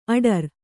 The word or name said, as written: ಅಠಾರಾ